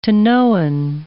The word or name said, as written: Tanoan